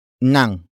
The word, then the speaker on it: nang: The next word is nang, which is spelled as ng.